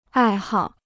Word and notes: 爱好: ài hào

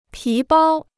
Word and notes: 皮包: pí bāo

